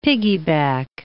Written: piggyback

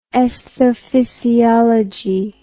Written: aesthophysiology

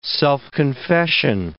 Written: self-confession

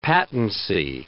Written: patency

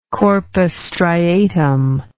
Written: corpus striatum

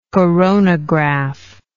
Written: coronograph

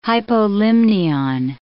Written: hypolimnion

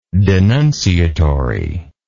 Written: denunciatively

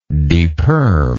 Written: deperm

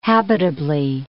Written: habitably

